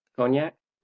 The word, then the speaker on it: cognac: how do I pronounce that? – cognac. – Cognac, there we go.